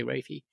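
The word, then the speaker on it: raphe: Although I've also heard people pronounce it as median Rafe nucleus, if they're French, but I've heard it Rafe in England, so I'll say Rafe.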